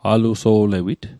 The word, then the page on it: aluso'lewit: Mi'gmaq